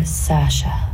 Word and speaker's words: Saoirse: It's Saoirse, but it's easier for Americans and English people to say Saoirse or Sasha.